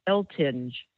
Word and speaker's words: Eltinge: You can find, also type in my name, Patricia Eltinge, and that's spelled E-L-T-I-N-G-E.